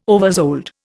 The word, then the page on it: oversold: Indian